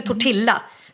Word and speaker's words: tortilla: Och det är därför tortilla. De uttalar inte ellen, utan det blir tortilla.